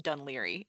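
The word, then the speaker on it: Dún Laoghaire: So it's pronounced Dunleary, but it's spelled D-U-N space. And this is where the Irish language makes no sense. L-A-O-G-H-A-I-R-E. And it's pronounced Dunleary.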